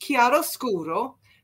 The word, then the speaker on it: chiaroscuro: It's pronounced chiaroscuro, and it's a term used to describe the treatment of light and shade in drawing and painting.